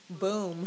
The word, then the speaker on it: Boom: Red, White, and Bloom. Just need to find like some. Oh, is it Boom or Bloom? – Boom. – Oh, is it? – Boom as in fireworks.